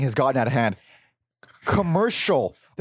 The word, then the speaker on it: Kamurshol: It's spelled K-A-M-U-R-S-H-O-L. Commercial. – That's a stretch, even phonetically.